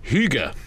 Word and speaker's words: hygge: And we focus particularly on the follow-up to the smash hit 2016 Danish trend of hygge. Chris, is that how it's pronounced? – Yes.